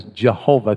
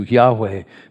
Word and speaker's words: Yahweh: Although that name is certainly not pronounced in Hebrew as Jehovah, because they don't have a J sound in Hebrew. It's really closer to Yahweh.